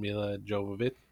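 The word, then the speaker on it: Milla Jovovich: But the Fifth Element is a great sci-fi action film with Bruce Willis and Mila Jovovich.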